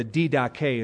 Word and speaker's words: Didache: A book surfaced called the Didache, or the Didache is the Greek pronunciation.